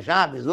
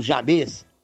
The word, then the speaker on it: Jabes: É uma pessoa muito famosa na Bíblia, porque Jabes, algumas pessoas falam Jabes, outras pronunciam como Jabez, que é o mesmo significado, né? Jabes ou Jabez é a mesma pronúncia.